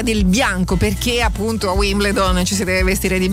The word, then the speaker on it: Wimbledon: sua potential su Radio Number One, donne con la racchetta, perché parliamo di Wimbledon, perché si dovrebbe pronunciare così. – Wimbledon. Sì, bisogna invertire le lettere. – Esatto, la L è invertita, quindi in inglese è perfect, proprio nel British.